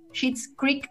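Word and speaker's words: Schitt's Creek: Se escribe S-C-H-I-T-T eh, guión eh, apóstrofe digo S-Creek. Sheets Creek, eh, esa la pueden encontrar en Paramount.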